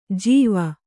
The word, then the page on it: ಜೀವ: jīva; Kannada